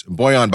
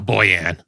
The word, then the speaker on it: Bojan: How do you say his first name? Well, he was on the Nets, Bojan Bogdanovic. – Ah, Bojan.